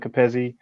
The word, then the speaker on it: Kapezi: Kevin Capezzi. My name is spelled KAPEZI.